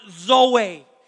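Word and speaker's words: Zoe (ζωή): The word life here in the Greek is pronounced Zoe. Zoe, life.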